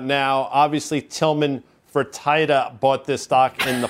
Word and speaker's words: Tillman Fertitta: Let the record show that you pronounce it Tillman Fertitta.